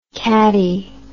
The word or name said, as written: cattie